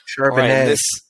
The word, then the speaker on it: Charbonnet: So with the 60th overall pick, the Bengals select Zach Charbonnet, running back at a UCLA. – Charbonnet. All right, Charbonnet. Thank you.